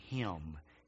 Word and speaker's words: hymn: That's what a hymn is, right? It's about, it's about him, although I know it's spelled H-Y-M-N, but a hymn helps us focus and set our mind's attention and our heart's affection on God.